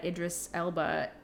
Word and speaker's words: Idris Elba: But I just am sad that Idris Elba doesn't get that. Did I say that right? – Yeah, you're right.